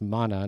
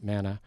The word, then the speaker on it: manna: I've been told it's pronounced mana, not manna.